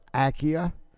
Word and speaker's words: Ackia: It's called the Battle of, it's pronounced several ways. It's a Chickasaw name, but the Battle of Akia. You can hear it both ways, A-C-K-I-A.